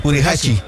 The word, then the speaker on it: Furuhashi: Tenemos todavía tiempo suficiente y pudiera darse la oportunidad para que ya sea Hachifuri. – Furuhashi. – Ah, Diosito. Tengo que estarlo leyendo, y aparte de eso, no me sale la pronunciación. Furuhashi.